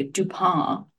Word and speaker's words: Dupin: The most important literary contribution Edgar Allan Poe ever made was the character of Dupin, or the French pronunciation. I didn't take French. Dupin. – It's just like a Dupin.